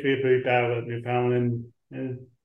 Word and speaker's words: Newfoundland: She went Ottawa to Newfoundland and now Sweden. – And by the way, nice pronunciation, Newfoundland, because people often get that wrong.